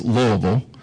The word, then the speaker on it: Louisville: And as Andy said when he stepped down, he was in Louisville, and by the way, it's pronounced Louisville.